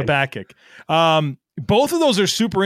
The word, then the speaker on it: Habakkuk: Habakkuk, actually, Habakkuk.